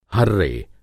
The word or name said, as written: हर्रे